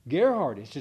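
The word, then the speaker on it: Geerhardus: What'd you say? – Gerhardus. – It's a hard G.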